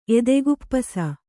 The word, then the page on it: ಎದೆಗುಪ್ಪಸ: Kannada